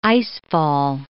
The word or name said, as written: icefall